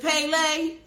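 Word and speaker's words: Pele (פלא): Now, in the Hebrew, and I may say it wrong, but they say that it's said Pele. I got somebody that studied Hebrew in the house. I'm looking right at them. Is it Pele? Okay. Close enough.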